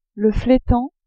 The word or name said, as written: fletan